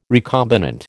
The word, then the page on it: recombinant: American English